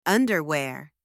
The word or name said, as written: underwear